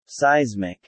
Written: Seismic

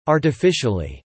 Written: artificially

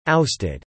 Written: ousted

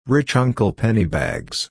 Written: Rich Uncle Pennybags

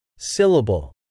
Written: syllable

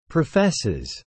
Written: professes

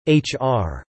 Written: HR

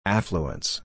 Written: affluence

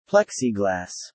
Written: plexiglass

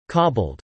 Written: cobbled

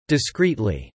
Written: discreetly

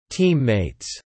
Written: teammates

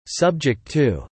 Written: subject to

